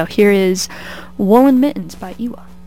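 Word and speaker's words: EWY: Next up, we've got Woolen Mittens by Ewa. That's how I'm choosing to pronounce it. It's E-W-Y, and I'm choosing to pronounce that as Ewa.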